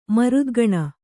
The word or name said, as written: ಮರುದ್ಗಣ